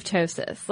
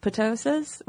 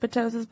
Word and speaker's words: ptosis: And honestly, I was somewhat disheartened to learn this because I feel like it's just pathologizing our ptosis, which, by the way, is spelled like it looks like it could be pronounced ptosis, which I have pronounced it ptosis before because that's more fun to say.